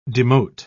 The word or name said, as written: demote